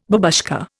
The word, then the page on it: babushka: Indian